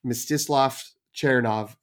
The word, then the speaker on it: Mstislav Chernov: And the director is Mstislav Chernov, who there's just a lot of vowels in there. So that's Mstislav Chernov is my understanding of how you should pronounce it based on likely AI generated Google, YouTube videos of how to pronounce it.